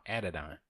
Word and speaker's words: Adidon: Phonetically speaking, it's Adidon. – Yeah. – Adidon.